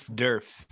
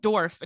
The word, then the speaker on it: Derf: It's Derf. – Yeah, I think they said Dorf and he was like, it's Derf. Like if Nerf had a D, Derf.